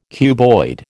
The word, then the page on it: cuboid: American English